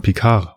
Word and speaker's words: Picard: Die Frage ist ja, würdest du ihn jetzt Picard aussprechen, also Englisch oder äh, Französisch, denn es ist nicht Picard, sondern Picard, weil dann ist das D stumm, meine ich.